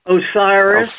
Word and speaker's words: Osiris: The name was Osiris. Am I pronouncing that wrong? – Osiris. – Osiris.